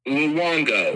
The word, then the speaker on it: Luongo: It's Italian. Luongo.